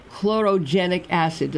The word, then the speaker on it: chlorogenic acid: They're not roasted, and these beans have more of a potent antioxidant called chlorogenic acid. Did I pronounce that correctly? – Sounds terrific to my ear.